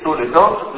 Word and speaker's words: Toledot: Toledot, i figli, c'è scritto Tav, Lamed, Dalet, Tav, si legge Toledot, ma la vava non esiste più.